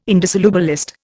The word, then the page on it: indissolubilist: Indian